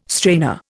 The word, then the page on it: strainer: Indian